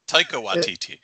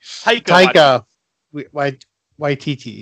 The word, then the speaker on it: Taika Waititi: Is it Taki? Is that how you say it? How do you say it? I know you know his name. – Taki Waititi. – Okay, Taika. That guy. – Taika Waititi. – Taika Waititi, yeah.